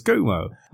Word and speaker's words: Cuomo: No, she's going to call in and tell you that the correct pronunciation is Cuomo.